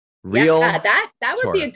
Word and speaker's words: Realtor: And I can pronounce it wrong sometimes. – Relator. – Like relator. – Realtor. – Yes.